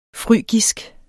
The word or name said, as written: frygisk